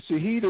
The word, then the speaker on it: Shaheed: And Shaheed is spelled S-H-A-H-E-E-D.